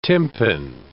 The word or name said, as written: tympan